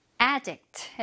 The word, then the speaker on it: addict: addict 동사일 때는 강세가 좀 여기로 오면서 addict 해서 이제 중독 되다 뭐 이런 뜻이 있는데 여기서는 형용사로 중독성에 이렇게 해석을 해주면 되겠습니다.